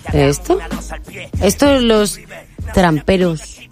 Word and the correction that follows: traperos: ¿Se dice así? – Traperos.